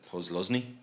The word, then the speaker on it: Posluszny: Posluszny? – Yep.